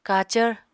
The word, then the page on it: kacvr: apatani